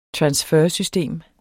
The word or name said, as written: transfersystem